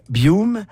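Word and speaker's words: Buium: Les Canucks, Marc-Éric, qui donne 75 millions à Ziv Bouillouma.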